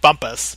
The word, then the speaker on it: Bumpass: Would be Roger Bumpass. – Whoa. – He is the voice of Squidward Tentacles in SpongeBob SquarePants. Although I think he actually pronounces his name Bumpus, but...